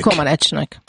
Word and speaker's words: Comaneci: Comanecinek.